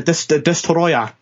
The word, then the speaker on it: Destoroyah: But one of my favourites, which I think you were about to go on to, just for the name, because nobody pronounces it properly, Godzilla versus Destoria. It's a Destoroyah.